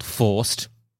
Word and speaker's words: Faust: So the game is, so you play a lovely lad called Faust. F-A-U-S-T. – Faust. – I believe is how you pronounce that. – Sure. – Like the man who the devil tricked.